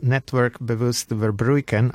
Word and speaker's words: Netwerk Bewust Verbruiken: And first of all, Network Bewust Verbruiken, apologies for my not ideal Dutch pronunciation. – It sounds great.